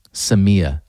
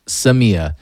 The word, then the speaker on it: Samia: Samia. I'm saying that right. – Yes. – Correct? – Samia. Correct. – Samia.